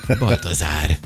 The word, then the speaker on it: Baltazár: A névnapokra Rafael és Baltazár. – Ja, én Bazárt olvastam volna, között szóltál. – Baltazár.